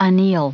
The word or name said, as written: anneal